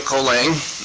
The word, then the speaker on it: Colang: My name is Daniel Colang. I am a DOC. – That's okay. – Would you spell that, please? – C-O-L-A-N-G.